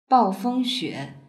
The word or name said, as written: baofengxue